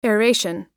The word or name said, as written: aeration